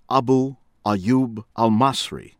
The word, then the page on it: Abu Ayyub Al-Masri: American English; from Egypt